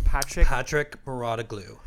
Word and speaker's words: Patrick Mouratoglou: Patrick Mouratoglou.